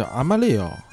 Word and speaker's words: Amarillo: 这当然了，也有人说呢，它的这个 Amarillo 啊，这其实西班牙语发音好像叫 Amarillo 啊，Amarillo。这个 anyway，反正我西班牙语也是跟我的这个阿拉伯语差不多啊，就反正就是俩字儿啊，不会。也有人说它这个黄啊，其实是来自于一种黄色的草。这个城市以前也不叫 Amarillo，